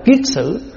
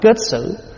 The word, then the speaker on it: kiết xử: Cho nên gọi là phiền não là cái mà nó sai xử mình, gọi là xử, là cho nên gọi là kiết xử. Chữ kiết là cột, chữ xử là nó sai mình. Có khi mình đọc là kết xử.